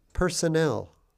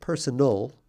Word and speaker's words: personnel: Personnel. Personnel are the people employed in an organization. Notice the pronunciation, personnel, not personal. Not personal, but personnel. That's a double N-E-L.